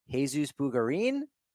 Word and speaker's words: Jesus Bugarin: Jesus Bugarin? And correct me if I've said that wrong. wrong. – No, you got it right.